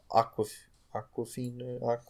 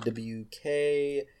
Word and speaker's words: Awkwafina: It's Awkwafina. That's how you pronounce it. Awkwafina.